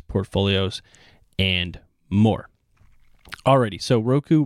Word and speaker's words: Roku: So Roku, people pronounce it differently. I pronounce it Roku, reported a narrower than expected Q4 loss of 13 cents per share.